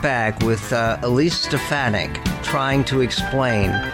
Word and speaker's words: Stefanik: And that is, by the way, how she pronounces her name. I've heard her say her own name. That's how she says it. Stefanik. And you can write it out. It's S-T-E-F and then A-H capital, because that's where the emphasis is.